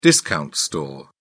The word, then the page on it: discount store: British English